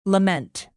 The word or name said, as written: lament